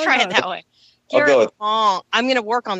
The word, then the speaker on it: Wang: Wang is Wong, believe it or not. So I am so sorry. Garrett Wong. – It's pronouncing it the way that most 99% of Americans would pronounce it because it's spelled A, and Americans usually pronounce an A hard and not soft, right? – So it's Garrett Wong.